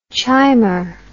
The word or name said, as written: chimer